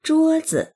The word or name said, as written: zhuo1zi